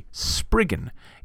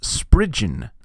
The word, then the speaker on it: Spriggan: Now, I have always called this creature a Spriggan. It has two G's, and that is a hard G sound, typically, in English. Not absolutely always, because there's no absolutely always in English. But apparently this is a Cornish word, and it is traditionally pronounced Spridgen.